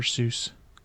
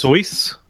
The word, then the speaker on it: Seuss: I thought you were going to say Dr. Seuss. – Apparently it's pronounced Soyce or something like that.